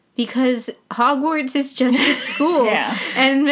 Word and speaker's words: Hogwarts: Hogwarts.